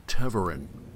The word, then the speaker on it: Tevarin: Because it'd be weird to see Tavarin in a bar in most places. Right. – Stuff like that. I believe it's pronounced Tevarin. Tevarin is the pronunciation.